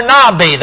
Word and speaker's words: Nabi: It comes from a Hebrew word, that is if you're studying the prophets in the Old Testament, a Hebrew word, N-A-B-I, pronounced Nabi.